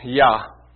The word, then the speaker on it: Jah: It is spelled J-A-H. It's pronounced Yah.